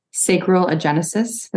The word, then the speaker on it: sacral agenesis: You were born with correct me if I pronounce this wrong, but sacral agenesis.